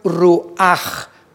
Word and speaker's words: ruach: La palabra nepej es diferente a la palabra ruach, que también se pronuncia ruach con J porque termina en CH en el hebreo.